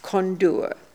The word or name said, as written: Kondoor